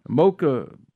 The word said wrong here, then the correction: maca: powder, okay. – Maca. – Okay, whatever you'll have it pronounced.